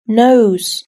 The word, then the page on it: nose: British English